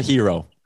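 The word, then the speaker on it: gyro: Which one's more of a hero? – Wow. Donair save lives. And also a Donair derivative, the G-Y-R-O, I believe is pronounced hero anyway.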